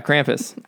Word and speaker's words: Krampus: I don't like I think you're talking about Krampus. Am I pronouncing that right? – Krampus? – Yeah, Krampus.